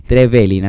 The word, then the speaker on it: Trevelin: Trevelin, acento en la segunda E, querido, ¿eh? ¿Sí? a ver, todos practicando en este momento. Trevelin, no es Trevelin ni Trevelin, es Trevelin. Espero que quede claro.